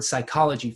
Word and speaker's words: psychology: We get the word psychology from it. We make the P silent, but it would have been pronounced in the original Greek. Psychology is, ology comes from logos, a word about the psyche, about the soul, psuche.